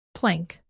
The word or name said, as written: plank